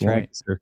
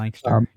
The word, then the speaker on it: Lancaster: So, and then also Lancaster, which nobody but people in Pennsylvania know how to pronounce that word. – It's Lancaster. Right. Lancaster, yep, Lancaster.